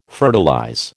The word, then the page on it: fertilize: American English